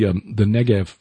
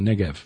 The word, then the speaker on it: Negev: But so, it may be pronounced with a V, because spelled with a B are actually pronounced as a V. So. – Well, that's my point. That one that one most certainly is, because today we know it as the Negev.